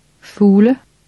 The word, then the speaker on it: fugle: The G is silent. – Fugle.